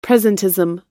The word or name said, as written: presentism